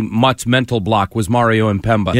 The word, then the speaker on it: Mpemba: Mario Mpebra. Am I saying that right, Ken Laird? I hope I am. Formerly the Detroit Tigers. – He threw like an extra R in there. He threw like an R in the last name.